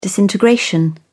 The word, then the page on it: disintegration: British English